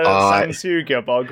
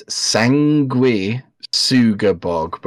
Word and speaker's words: Sanguisugabogg: This is, I think, so here's my pronunciation. I think it's Sanguisugabogg, because sangui is like blood, right? And I assume Sugabogg is some other thing. I don't know what it is, but that would make sense if it's Sanguisugabogg.